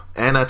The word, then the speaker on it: Anya: Is it just Anna? A-N-Y-A? Yeah. Anna Taylor-Joy.